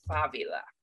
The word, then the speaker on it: Favila: Favila. – With a B? – Actually with a V, but it's pronounced the same in Spanish.